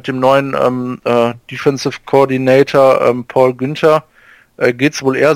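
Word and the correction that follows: Guenther: Gunter wird er ausgesprochen. – Ja, da wird aber mit UE geschrieben. – Naja, ich glaube, der wird Gunter in Amerika ausgesprochen.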